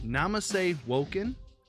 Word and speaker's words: Namaste Woken: All right, guys, we're sitting here with Namaste Woken. I think I said that properly. – Yep, you did.